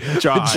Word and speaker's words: Jaun: It's actually J-A-U-N. Like John.